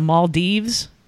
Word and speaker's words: Maldives: So, there's this couple that went on their honeymoon in the Maldives.